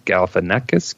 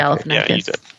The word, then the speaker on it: Galifianakis: Yeah, I will say I also really enjoy Masterminds um, with Zach Galifianak- Galifianakis. that's I think you said it right the first time. – Yeah, okay. Galifianakis.